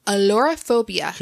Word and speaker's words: ailurophobia: Alarophobia. – Could you spell it, please? – A-I-L-U-R-O. P-H-O-B-I-A.